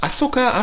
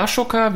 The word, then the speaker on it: Ahsoka: As Ahsoka? Ashoka? Wie spricht man das richtig aus? Ahsoka. – Ahsoka, ah, Ahsoka Tano. – Nee, As Asoka. – Ahsoka, ja. Asoka.